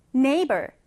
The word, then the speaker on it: neighbor: 자, 먼저 N-E-I 그대로 발음해 주시고요. 이 G-H는 없다고 생각하세요. 물론 발음할 때만 없다고 생각하세요. 그러면 neighbor 이렇게 발음하시면 되는 겁니다.